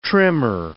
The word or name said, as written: trimmer